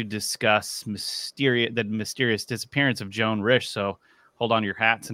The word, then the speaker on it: Joan Risch: Joan Risch. – Pronounce that. – Yep.